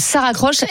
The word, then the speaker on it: Saracroche: Donc, Ça Raccroche, S-A-R-A-C-R-O-C-H-E. – Oui, comme ça, ça se prononce.